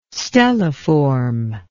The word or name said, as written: stelliform